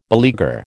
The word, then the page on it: beleaguer: American English